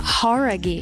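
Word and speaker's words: Jauregui: Horaghi. Não é? – Horaghi. – Horaghi. – Então a gente tava falando errado.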